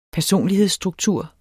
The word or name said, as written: personlighedsstruktur